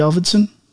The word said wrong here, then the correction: Avildsen: I just don't know if I'm pronouncing his name correctly or getting it right. John G. Avildsen.